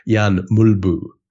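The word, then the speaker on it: Jan Mølby: And he used to go out of his way to refer to him every time he touched the ball as Jan Mulbu, which is apparently how you say it properly.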